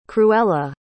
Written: cruella

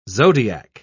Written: zodiac

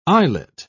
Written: eyelet

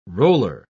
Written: roller

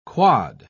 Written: quad

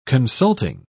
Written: consulting

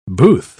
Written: booth

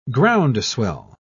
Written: groundswell

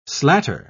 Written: slatter

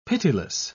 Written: pitiless

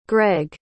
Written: greg

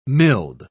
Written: milled

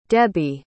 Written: debi

